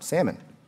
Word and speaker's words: Salmon: Now, by the way, Salmon, I mean, if it's a fish you eat, the L is silent, salmon. But this man, I think he's Salmon.